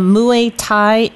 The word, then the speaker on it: Muay Thai: Now, before we go to the break, could you explain to me what a Muay Thai match is? – Yeah, sure.